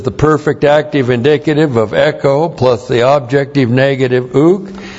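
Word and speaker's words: Echo (ἔχω): E-C-H long O. Echo.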